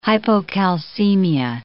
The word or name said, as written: hypocalcemia